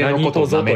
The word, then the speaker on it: 何卒: これはね、何とぞと読みます。